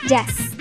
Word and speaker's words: yaz: Fuera se dice yaz y se escribe con Z al final. – Yaz. – Yaz. – Y-A-Z. – Y-A-Z, exacto.